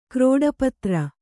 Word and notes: ಕ್ರೋಡಪತ್ರ: krōḍa patra

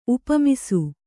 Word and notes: ಉಪಮಿಸು: upamisu

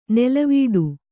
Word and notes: ನೆಲವೀಡು: nelavīḍu; Kannada

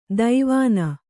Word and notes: ದೈವಾನ: Kannada

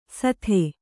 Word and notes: ಸತ್ತೆ: Kannada

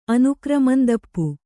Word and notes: ಅನುಕ್ರಮಂದಪ್ಪು: Kannada

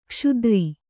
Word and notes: ಕ್ಷುದ್ರ: Kannada

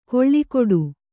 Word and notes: ಕೊಳ್ಳಿಕೊಡು: koḷḷi koḍu

